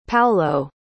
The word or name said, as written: Paolo